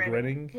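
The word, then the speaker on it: Gröning: And did I pronounce that right, by the way? It's Grenning? Oscar Grenning? – Yeah. – Yeah, okay. – Yeah, I think that's good.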